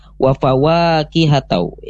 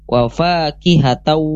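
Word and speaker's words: wa faqihatau (وفاكهة): Tadi adik Jama membacanya wa fawaqihatau ya, ada tambahan waw yang kedua ya. Nah, harusnya wa faqihatau wa abba. Tadi adik Jama wa fawaqihatau.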